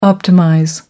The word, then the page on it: optimize: American English